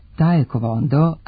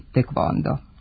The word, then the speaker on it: taekwondo: godine zaista piše da se taj korejski sport zove taekwondo, a ne tekvondo. Pravopisci su se odlučili za oblik koji smo dobili posredstvom engleske transkripcije, a ne za izvorni korejski izgovor koji glasi tekvondo.